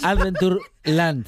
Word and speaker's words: Adventureland: Lo has dicho mal. Has dicho Adventure. Lo he dicho bien, porras. – Adventureland. – Dijiste Adventure. – Adventure.